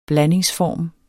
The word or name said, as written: blandingsform